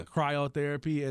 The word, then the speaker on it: cryotherapy: And like you say, you go, what was it, chirotherapy? Is that how you say it? – Or yeah, cryo. Yeah. – Cryotherapy.